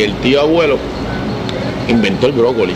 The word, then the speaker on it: Broccoli: C-U-B-B-Y. – Ok, y brócoli es como el... Brócoli, como el brócoli.